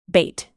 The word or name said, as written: bate